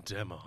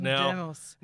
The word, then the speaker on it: Demos: You've got to say it properly. Adam Demos. – Adam Demos.